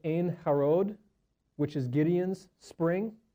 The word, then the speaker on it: Ein: So we have Ein, which is a fountain, kind of like, you know, pronounced like ain't without the T.